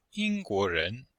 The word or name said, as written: ying1guo2ren2